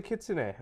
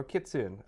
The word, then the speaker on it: kitsune: Anyways, we talk about the kitsune or kitsune. Again, I've heard it pronounced as both from both Japanese people.